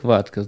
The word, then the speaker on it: vodka: Вот это произношение, как говорят, penis with vodka sauce, или там вы идете в бар, вы просите водка. Оно звучит как ватка. Знаете, вот это вот медицинская ватка. Вы хотите там в ухе поковыряться, вы говорите, дай мне ватку. Так вот, вот это слово произносите как ватка. Ну, может быть, Т немножко чуть-чуть больше произносите как Д.